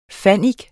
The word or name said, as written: fannik